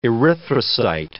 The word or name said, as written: erythrocyte